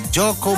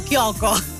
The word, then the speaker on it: Kyoko: Questa è una canzone del 1997, Alan Sorrenti, Gioco Mon Amour, però si scrive con la K. – Infatti stavo leggendo Kyoko. – Kyoko Mon Amour. – Meno male che l'hai disannunciata tu, se no io avrei detto Kyoko.